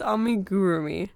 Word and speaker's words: Amigurumi: Because it's Amigurumi or it's Amigurumi. It depends how hard you put that second U sound. – It's Amigurumi. – Yeah. – That's how it's spelled. – But when you say it too fast, it's like Amigurumi. – I think a lot of people say Amigurumi. Like they put in an extra M.